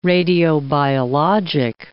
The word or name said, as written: radiobiologic